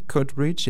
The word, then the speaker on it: Coatbridge: Alors Airdrie, Coatbridge et Greenock. C'est ça, je les prononce sans doute assez mal, mais je vais vous laisser le faire peut-être. – Non, non, c'est très bien prononcé.